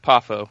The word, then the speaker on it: Poffo: Uh, his real name is pronounced Randy Poffo, right? – Yep, Poffo. Okay, yep.